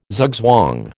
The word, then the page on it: zugzwang: American English